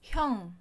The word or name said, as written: 형